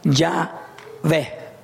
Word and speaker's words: Yahweh: Los hebraístas han llegado al acuerdo general de que la pronunciación original debe de haber sido Yahvé.